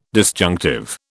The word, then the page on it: disjunctive: British English